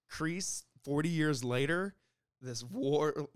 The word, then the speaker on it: Kreese: Even I said, like, at the beginning, when you first see Kreese, damn it. I keep saying his name wrong, damn it. – Kreese. – Kreese.